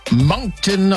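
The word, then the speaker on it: Monkton: To Moncton, Ontario. I think I'm saying that right. John is there. Hi, John. – Yes, you are.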